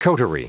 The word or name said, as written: coterie